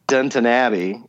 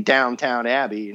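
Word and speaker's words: Downton Abbey: Well, I got to get through like the next 36 episodes of Dunton Abbey, as I was told to pronounce it, because I keep saying Downtown Abbey, and that sounds like a hooker down on the street.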